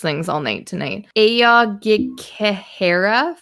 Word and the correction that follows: Aokigahara: Forest. Do you know how to say this? – One second. – Aoghikehara Forest. – Aokigahara. – Mm-hmm. Aokigahara. That sounds way better.